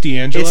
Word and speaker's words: D'Angelo: Dangalos steak and cheese for life, son. – Dangalos. – It's actually just D'Angelo. – It's D'Angelo? – It's just D'Angelo. – Yeah, there's no S at the end, right? – Right.